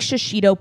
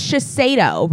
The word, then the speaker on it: Shiseido: It's not like Shishido Peppers. It's like it's Shiseido, right? – Shiseido? – I'll let you guys know on May 26th.